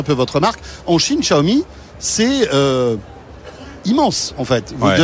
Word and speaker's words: Xiaomi: Avec la prononciation de Xiaomi aussi. – Tout à fait, Xiaomi.